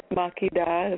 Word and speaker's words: Makedah: So for the listeners, check me out, Queen Makedah is how I pronounce it, .com.